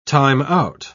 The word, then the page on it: time-out: British English